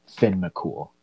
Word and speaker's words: Finn McCool: Like I think the most traditional way is it's more like Thean Mac Kumhale is how it's spelled. Like if I was to say it phonetically how it's spelled, but it should be pronounced Finn McCool.